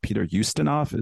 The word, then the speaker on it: Peter Ustinov: and I watched Peter Ustinov.